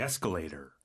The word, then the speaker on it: escalator: Hold the handrail when you get on the escalator. – Hold the handrail when you get on the escalator. 그 escalator, escalator. 이거를 발음할 때 escalator. – Escalator. 그래서 끝 발음이 나게끔 yeah. escalator라고 해야 잘 알아듣더라고요.